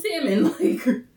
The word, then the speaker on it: salmon: Might as well eat candle wax and pronounce the L in salmon.